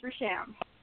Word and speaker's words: Rasham: how do so how do I pronounce your name properly? I mean, is it Rasham or is That's it Rasham? How do you pronounce? You no, you got it the first time. It's Rasham.